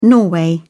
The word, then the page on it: norway: British English